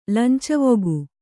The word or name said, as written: ಲಂಚವೊಗು